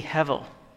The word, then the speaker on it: Hevel: And so it's so obvious, she names her second child Abel, which in Hebrew, you put a little H sound over the A and the B's are pronounced with a V, and so it would be Hevel.